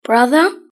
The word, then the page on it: brother: British English